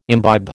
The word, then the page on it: imbibe: American English